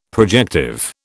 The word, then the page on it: projective: British English